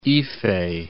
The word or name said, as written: Ife